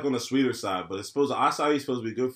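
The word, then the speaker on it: açaí: Acai. It's spelled A-C-A-I. You ever see nothing spelled A-C-A-I? – Nah.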